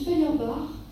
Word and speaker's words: Feuerbach: On trouve aussi ça chez Feuerbach, ça s'écrit comme Feuer en allemand, F-E-U-E-R, Bach, B-A-C-H.